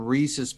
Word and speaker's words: Reese's: I do know it's pronounced Reese's, and I do know that I was pronouncing it wrong.